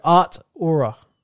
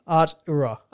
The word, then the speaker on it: Artura: And how do you say it then? A-R-T-U-R-A. Artura.